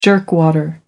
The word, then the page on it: jerkwater: American English